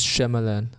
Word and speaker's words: Shyamalan: Er wird übrigens Shyamalan ausgesprochen.